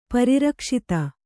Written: ಪರಿರಕ್ಷಿತ

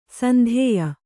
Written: ಸಂದೆಯ

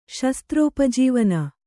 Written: ಶಸ್ತ್ರೋಪಜೀವನ

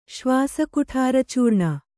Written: ಶ್ವಾಸಕುಠಾರಚೂರ್ಣ

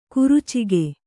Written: ಖರ್ಚಿಕ

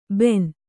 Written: ಭೇನ

